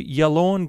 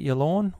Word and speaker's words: Yallourn: And we're going to start in Victoria to Yallourn Golf Club. I think that's how you pronounce it. Yallourn. Y-A-L-L-O-U-R-N.